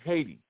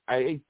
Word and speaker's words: Haiti: And we ain't even pronouncing the name right because my sister Bayano Bayola would tell me it's not Haiti, it's Haiti. Right? – I heard that before.